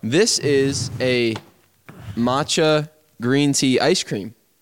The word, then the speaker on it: matcha: Matcha, yeah.